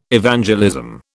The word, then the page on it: Evangelism: British English